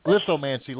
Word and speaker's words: lithomancy: Which book is that, the Lithomancy or the Second Eternity? – Yes, the Lithomancy. I can't even say it. – Lithomancy, like lithograph.